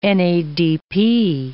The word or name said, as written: NADP